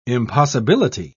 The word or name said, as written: impossibility